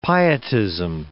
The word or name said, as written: pietism